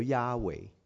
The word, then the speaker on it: Yahweh: 咁呢個呢四個字咧係冇得讀嘅，即係發唔到音嘅。咁咧就所以為咗能夠發音咧，咁啲人咧就加咗兩個響音落去。咁於是乎咧係成為咗 Yahweh 啊，YHWH Yahweh 就係我哋中文所譯做嘅雅威。